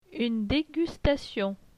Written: Une degustation

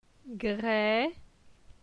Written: Gre

